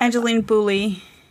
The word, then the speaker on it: Angeline Boulley: Because I don't have Angeline Bouley.